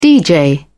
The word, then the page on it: dj: British English